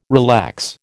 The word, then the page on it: relax: American English